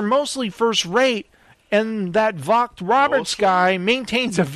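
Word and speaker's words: Vogt-Roberts: Unfortunately, director Jordan Volk Roberts... How do you say that? Volk Roberts? – Yeah.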